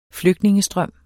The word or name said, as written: flygtningestrøm